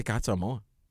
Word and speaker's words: Casa Amor: You got to say Casa Amor. – Amor. Yeah, that's how it's said.